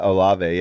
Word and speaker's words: Olave: I haven't watched him yet this Olave? – Week. Olave, yeah.